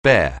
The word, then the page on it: bare: anglais